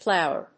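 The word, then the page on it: plower: plow･er; pláʊər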